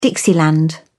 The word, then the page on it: dixieland: British English